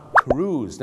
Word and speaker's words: cruise: cruise นั่นเองครับถูกต้องครับ cruise เหมือนทอมครูซนะฮะชาวไทยนะครับบางทีไปออกเสียงว่าครุย